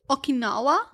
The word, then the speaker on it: Okinawa: Okinawa? – Yeah.